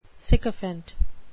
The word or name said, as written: sycophant